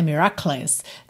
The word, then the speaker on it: miracles: Aqui uma palavra importante, miracles, porque se escreve M-I-R-A-C-L-E-S. Então, haveria aí uma tendência, né, se a gente fosse ler como se lê em português, miracles, né, miracles. Não, a gente pensa em call mesmo, tá? Como se fosse C-E-L, pode inverter aí o E e o L de posição só no seu pensamento, tá? Para você pegar essa pronúncia.